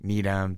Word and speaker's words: Needham: I was thinking Needham. – Yep, that was what I thought. – Because it ends with H-A-M, but it's pronounced M.